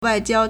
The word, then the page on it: 外交: wàijiāo